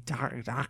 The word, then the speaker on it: Dacre: Oh, it's called Dracula the Undead, and it's by D-A-C-R-E Stoker. How would you even pronounce that? – Doc. D-A-C-R? – D-A-C-R-E. Docker.